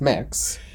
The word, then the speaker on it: Mx: I think September, October, maybe, where it's mix. MX, pronounced mix.